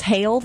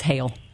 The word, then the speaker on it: Hale: It's Grace Hale, though, I'll just say. You've added a syllable, which is lovely. Maybe it sounds better that way, but it's Hale.